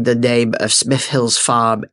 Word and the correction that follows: Smithills Farm: incorrectly. It is actually Smithills Farm, and I hope that satisfies the people out there that I've written to me with endless, endless letters.